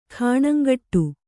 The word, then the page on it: ಖಾಣಂಗಟ್ಟು: khāṇaŋgaṭṭu